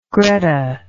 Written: greta

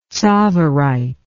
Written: tsavorite

